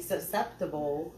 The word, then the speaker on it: susceptible: And then when you finally decide to make that split, you're easily susceptible, if I said that right, to dating again. Did I say it right? – You did. – I did. Y'all didn't hear that S, the second S in there.